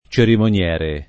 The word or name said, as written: cerimoniere